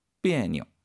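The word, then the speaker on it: 别扭: 역시 그 니오자의 발음 경성으로 읽는다는 거 가볍게. 그래서 비에뇨.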